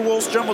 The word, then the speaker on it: Wallström: Så att lite oklarheter vad man ska uttala, hur man ska uttala Wallström. Men han säger själv Oliver Wallström, och då gör vi detsamma.